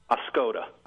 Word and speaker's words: Oscoda: Oscoda.